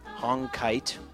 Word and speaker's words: Hongkiat: H-O-N-G-K-I-A-T. Hongkite.